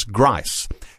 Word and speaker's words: Griese: But simply Googling Across in Time and Yvonne's name, Yvonne, and Yvonne's surname is Grice, but it's spelt Griese.